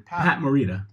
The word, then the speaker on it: Morita: Pat Morita.